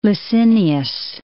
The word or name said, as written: Licinius